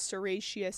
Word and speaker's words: Serratia: And they remember it as Sriracha sauce red, because Serratia sounds like Sriracha, so Sriracha sauce red.